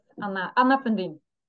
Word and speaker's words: Anna Fundin: Yes, Anna Fundin.